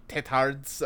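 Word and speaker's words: têtard: It's pronounced Tetard. – Tetard? So you like the D is silent? – Mm-hmm.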